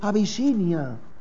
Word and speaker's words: Abisinia: Algunas veces decimos, Señor, bendice a los de Abisinia. Y no nos preocupamos de la Abisina. Es que no hay que evangelizar a la Abisina. Está mal pronunciado, pero me han entendido porque ustedes son inteligentes, sí o sí.